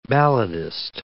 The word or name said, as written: balladist